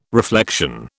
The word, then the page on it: reflection: British English